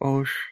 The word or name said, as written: osh